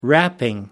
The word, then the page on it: wrapping: American English